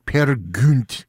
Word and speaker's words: Peer Gynt: Anitra's Dance from Peer Gynt by Edvard Grieg, or as Thomas Sundegaard says, Peer Gynt. That would say his proper pronunciation on that one, and he would know, exactly.